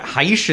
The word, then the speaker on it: Gijs: Heish is how you say G-I-J-S. This is why we're screwed pronunciation-wise.